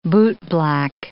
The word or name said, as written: bootblack